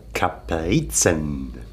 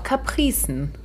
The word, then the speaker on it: Kaprizen: Da sind wir uns noch nicht ganz sicher, beziehungsweise wir lassen mal beide Aussprachen gelten. – Es kommt halt darauf an, welche Sprache. Im Deutschen schreibt man es tatsächlich mit K und mit Z. Aber es kommt wohl aus dem Französischen, wobei vielleicht wieder aus dem Lateinischen mit C und C. – Capricieux. Aber ähm, ich finde auch ein Z kann man ruhig scharf Kaprizen aussprechen.